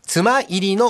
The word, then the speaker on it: 妻入り: 中でも有名なのが中越地方、出雲崎町に残る妻入りの街並みです。奥さんが入ると書いて妻入りと読みますね。